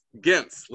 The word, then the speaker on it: Gintz: It's a hard G, it's a Gintz. – Gintz, Lisa Gintz, yeah.